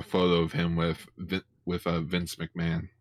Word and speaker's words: McMahon: No, it's McMahon, guys. The H is silent.